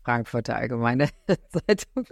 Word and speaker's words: Frankfurter Allgemeine Zeitung: Frankfurter Allgemeine Zeitung.